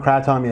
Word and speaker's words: Kratom: Kratom, yeah.